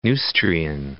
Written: Neustrian

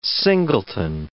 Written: singleton

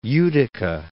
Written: Utica

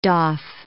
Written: doff

